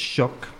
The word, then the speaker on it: Xoc: It may derive from the Yucatec Maya Xoc, X-O-C, pronounced shock, or maybe German shirk, which is scoundrel.